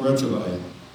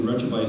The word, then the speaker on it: Retrouvaille: It is spelled R-E-T-R. O-U-V-A-I-L-L-E. It's a French word that means to rediscover. Marie and I have been involved in Retrovi since 2013.